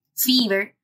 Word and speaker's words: Fiverr: hay uno que se escribe e F-I-V-E-R-R, Fiverr, que esa plataforma precisamente se llama, bueno, se llama Fiverr, porque lo mínimo que te cobran por un trabajo son 5 dólares.